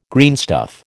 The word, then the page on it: greenstuff: American English